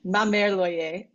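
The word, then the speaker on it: Ma Mère l'Oye: which I think it's pronounced Ma Marie Loyer Petit Poussé by Maurice Ravel. – Close. It's Ma Mère Loyer, I believe.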